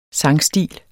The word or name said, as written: sangstil